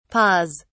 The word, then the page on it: paws: British English